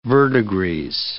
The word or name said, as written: verdigris